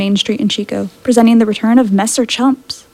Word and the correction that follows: Chups: Chups. I always say that wrong.